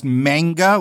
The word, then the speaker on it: manga: It's pronounced manga, like mango. That's definitely how it's pronounced. – So it's manga, like Manos, Hands of Fate.